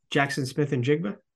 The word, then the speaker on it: Smith-Njigba: I'm going to butcher the Ohio State receiver's name Jaxon Smith-Njigba.